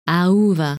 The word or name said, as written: Auva